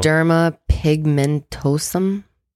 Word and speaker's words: Xeroderma pigmentosum: Xeroderma pigmentosum. – Good. Very good. That's exactly how it's pronounced.